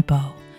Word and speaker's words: beau: And this is actually another word people sometimes use for boyfriend, my beau, pronounced to rhyme with toe.